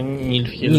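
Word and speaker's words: Нифльхейм: Я правильно его произношу? Да, Нильфхельм.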